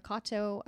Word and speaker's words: Katow: So it's at Sierra Kato, spelled like my name, K-A-T-O-W.